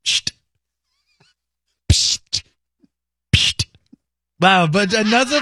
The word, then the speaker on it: Pschitt: It's spelled P-S-C-H-I-T-T. And it is How would you pronounce that? – Because that's the sound of opening.